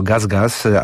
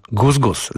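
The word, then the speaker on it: GusGus: A w nim w dniu premiery, także u nas, odsłona najnowszego utworu kolektywu Gus Gus, grupy artystów w Polsce częściej wymawianej jako Gaz Gaz, ale ta przyprawa w filmie Fassbindera brzmiała Gus Gus.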